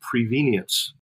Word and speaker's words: prevenience: We have a word that we've learned from Eugene Peterson, which is the word prevenience. Rhymes with convenient and simply means before.